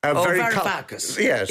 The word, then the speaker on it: Varoufakis: And interestingly enough, I read that the Greek finance, former finance minister with the name I can never pronounce, Varoufakis. – Varoufakis.